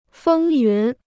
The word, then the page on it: 风云: fēng yún